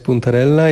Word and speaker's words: puntarella: Ta cykoria, którą próbowaliśmy, to puntarella. Dobrze wymawiam? – Tak jest. To jest puntarella.